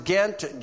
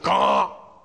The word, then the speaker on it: Ghent: We pronounce it Ghent, G-H-E-N-T. The French pronounce it Gaunt. So we went to Gaunt.